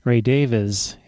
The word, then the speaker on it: Davies: or actually Ray Davies is his pronunciation. He says so, but what does he know? He made a comment one time on NPR. I don't know why Americans always say Ray Davies.